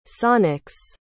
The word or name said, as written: sonics